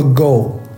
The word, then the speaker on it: go: 比如说大家知道我们围棋如果用汉语拼音读的话是围棋，但是用这五个字母去跟白人说很少有人懂，但是你要说 go G-O 白人都懂，但是这是日语围棋的发音啊。